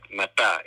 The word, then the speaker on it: mata: I mean, like the word for Proto Philippine mata is exactly that, mata, M-A-T-A, accent on the final A.